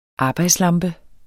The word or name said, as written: arbejdslampe